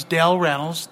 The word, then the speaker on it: Del Reynolds: My name is Del Reynolds, D-E-L-R-E-Y-N-O-L-D-S.